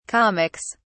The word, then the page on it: comics: British English